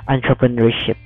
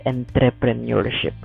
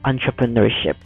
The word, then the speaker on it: entrepreneurship: By the way, the pronunciation is entrepreneurship, not entrepreneurship. It's entrepreneurship. There you go.